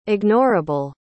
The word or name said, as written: ignorable